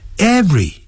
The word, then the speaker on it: every: 但是有时候我们就不能加任何的介词，比如说用 every 开头的时候， every 也是两个音节，不注意时会念成 every，没有这个念法。